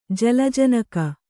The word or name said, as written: ಜಲಜನಕ